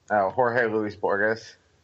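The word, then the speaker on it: Bourjos: They signed Peter Borjo? How do you say his name? – I've always pronounced it like Borges because I think people called him Gorgeous Borges for a while.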